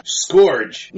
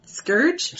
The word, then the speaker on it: scourge: You mean scourge? – Scourge. Like the whips? – Yeah, the whips. – Oh. I've always heard it pronounced scourge. – S-C-O-U-R-G-E. You can pronounce it either way.